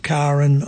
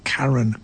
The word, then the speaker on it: Karrin: So anyway, Alan Broadbent's on piano, and Alan and Karin are backed by a studio band. Please have a listen to Karin. It's spelled K-A-R-R-I-N, but she pronounces it Karin, not Karen.